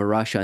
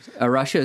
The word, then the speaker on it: Arocha: Arasha is a Portuguese word, and I'm certainly not pronouncing it correctly, but it's the way we pronounce it in the U.S. for Arasha, A-R-O-C-H-A, is a Christian conservation organization.